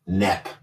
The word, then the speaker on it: Knepp: I think Michael Knepp, K-N-E-P-P. He stole the sound of the K. – That's a silent K because he's the silent killer.